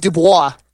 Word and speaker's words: Dubois: And I'll be Daxon Dubois. I'm dead serious. It's spelled D-U-B-O-I-S. A little French right there.